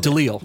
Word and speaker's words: DeLille: DeLille? How do I say that? DeLille. – DeLille. Yes. Okay.